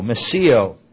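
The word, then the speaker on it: meseo (μισέω): And this is the Greek word, meseo. M-I-S-E long O, meseo.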